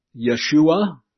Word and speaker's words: Yeshua: And in the Hebrew, it was pronounced Yeshua, basically.